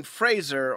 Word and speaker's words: Fraser: We had Brendan Fraser on the episode earlier. – Nice pronunciation of Fraser. You didn't mess it up for the first time.